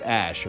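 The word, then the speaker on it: Æ: So they had to change his name to X Ash, which is the A and E letters slammed together. That's pronounced Ash, apparently.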